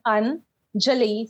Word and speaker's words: Anjali: Coming back to how do I pronounce my name? It's like Anjali.